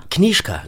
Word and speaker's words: Knizka: Knischka oder Knischka? – Knischka, so wie, wie das Knie und ein Sch dran.